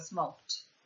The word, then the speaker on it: smoked: Sentite che non c'è il did, però c'è la ed messa a smoke, che per la regola fonetica vista prima si pronuncia smoked come una T.